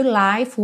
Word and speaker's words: life: Então, so no one told you life was gonna be this way. E também life was. Lembra que o E do life não é pronunciado. Então, você vai juntar o F já no W.